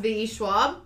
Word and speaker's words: V.E. Schwab: It's V.E. Schwab.